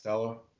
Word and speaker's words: Costello: Is that how you pronounce his last name? – Costello? – Kiffin Stanford, the quarterback. Costello. There we go.